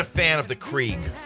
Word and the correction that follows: Keurig: Keurig? – Yeah. Yeah. – It's pronounced Keurig.